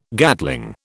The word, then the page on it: Gatling: British English